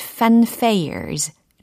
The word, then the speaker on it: fanfare: The buttons and the pins and the loud fanfares 라는 부분입니다. 단추들과 핀과 시끄러운 빵빠르도 봐요 라는 의미겠죠. 네, 빵빠르, 뭐, 소위 팡파레라고도 이야기를 할 수 있는 단어인데, 영어로는 fanfare 이렇게 발음이 됩니다.